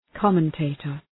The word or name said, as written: commentator